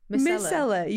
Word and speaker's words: Micellar: Is Marcella water. – Marcella, fucking hell. How do you say it? – Micellar.